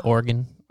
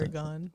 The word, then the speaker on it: Oregon: I said the name wrong. I said Oregon. I don't know how to pronounce Oregon.